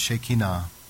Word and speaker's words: Shekinah: But in Hebrew, it's pronounced Shekinah.